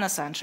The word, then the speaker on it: Ассанж: мы его называем Джулиан Ассанж, а не с какими-то другими вариациями.